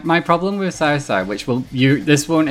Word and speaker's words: Cao Cao: And they say Cow Cow. It's not how you say it. – Cao Cao.